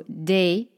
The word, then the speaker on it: des: So, des des rhymes with les, les, that we just looked at together.